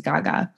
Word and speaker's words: Gege: Her name is Gaga, but it's spelled G-E-G-E.